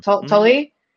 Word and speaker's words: Tolle: Is that how you say his last name? – Yeah, Tolle. I never know how to say it. – Yeah, that's it.